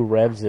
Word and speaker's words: REVS: And I happen to see all over Midtown Manhattan and Lower Manhattan Who is Rez? – What, Rez? – Who is Rez? – What, is that how it's spelled? – R-E-V-S. – R-E-V-S, Revs.